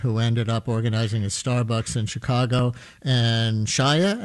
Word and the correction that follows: Kaya: am I saying her name right? – Kaya Barrett. So yeah, Kaya Barrett is a fascinating person.